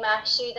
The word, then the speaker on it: Mahshid: So I usually say my name, Mahshid, and weirdly everyone hears, a lot of people actually hear Ashley.